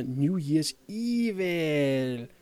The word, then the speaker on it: New Year's Evil: For at lindre tømmermændene efter nytårsaftens fest, så skal vi se noget af en øh, tømmermandsfilm, som hedder New Year's Evil. Og det skal udtales lige nok det på den måde.